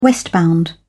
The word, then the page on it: westbound: British English